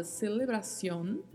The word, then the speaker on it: celebración: Temos ainda o lá, artigo feminino a, a palavra celebración, celebración, que é o substantivo feminino que significa celebração, e bastante atenção com a pronúncia final aí da letra C, porque pode ter variação a depender da região. Pode-se falar celebração ou C tendo o som de S mesmo,